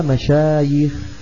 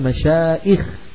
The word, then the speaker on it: مشايخ: إذن باعتبار عينها حرف علة تجمع على مشايخ، ولا يقال مشائخ، هذا غلط.